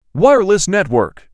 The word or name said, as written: wireless network